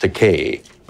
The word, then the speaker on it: Takei: When I walked into Gene Roddenberry's office, first of all, he mispronounced my surname. He called me Takei, but I corrected that. I said, my name is pronounced Takei, but I don't object to Kai, because that's a Japanese word that means expensive.